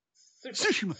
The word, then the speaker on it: Tsushima: No, Tsushima.